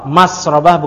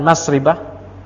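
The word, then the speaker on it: masrabah: Bi fathir ra, masrobah bukan masribah. La ghair, enggak ada yang lain. Mesti bacanya masrobah.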